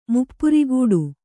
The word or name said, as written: ಮುಪ್ಪುರಿಗೂಡು